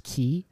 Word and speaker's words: Quay: Yeah, Quay. Some people say it's pronounced Key. There's a great debate about that, but it's an Australian brand.